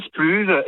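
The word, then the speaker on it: splouj: Le splouge, et bon. Comme en breton, il y en a une dizaine d'accents, on peut le dire le splouge, tout ce que vous voulez. Ça s'écrit avec un J à la fin, il n'y a pas de souci.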